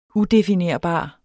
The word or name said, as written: udefinerbar